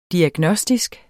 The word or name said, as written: diagnostisk